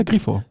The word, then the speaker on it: Grifo: Ja, Grifo. Ich wollte den amerikanisch aussprechen, ich weiß gar nicht warum.